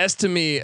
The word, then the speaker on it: Estime: How do I pronounce this guy's name again? – Audric Estime. – Yeah, Estime.